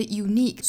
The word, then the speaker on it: unique: 大家注意，这里面的 unique 虽然是以元音字母 u 打首，但是从发音角度来说啊，它是辅音打首，所以跟前面的元音是连读不上的。